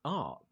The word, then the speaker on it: Arc: It's ARC. So that's ARC with a C, A-R-C, not ARC with a K, which is also an Intel tool for looking up CPU specs.